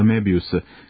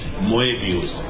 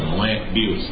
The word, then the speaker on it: Moebius: Kada smo seli da razgovaramo, ja sam ga pitao, u moje zemlji vas zovu Mebius. Kako se vaše ime izgovara? I on mi je rekao, my name is Moebius. Moebius. I to je baš tako razdvojio, Moebius.